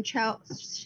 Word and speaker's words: Chell: Because that is referred to like I don't know how to pronounce her name, Chell's. Like her only friend, right? – It's Chell. – Okay, thank you very much.